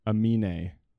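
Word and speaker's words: Aminé: Amine. – Amine. All right. – It's like the vowels in anime are the same.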